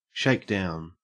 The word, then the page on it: shakedown: Australian English